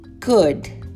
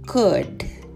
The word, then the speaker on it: could: Could. Could. Isso mesmo, a grafia tem L, mas a pronúncia não.